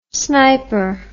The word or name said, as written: sniper